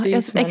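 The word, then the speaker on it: Deasismont: Desm Desismont eller ja, Desmont. Exakt, ja.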